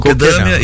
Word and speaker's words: Kokedama: O próximo aqui, coquedama é assim mesmo a pronúncia? – Isso, Kokedama, e Terrário.